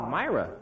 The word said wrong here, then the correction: myrrh: That's the way you say it in the pageant, too. – That's wrong, huh? – Uh-huh. It's myrrh, like gold, frankincense, and myrrh.